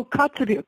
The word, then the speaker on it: Kotzebue: But there's a village, maybe it's pronounced Kotzebue or. – Oh, Kotzebue. Kotzebue. Yeah.